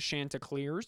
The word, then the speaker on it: Chanticleers: It's they got a unique name, the Chanticleers. – Yeah, which is how you pronounce it.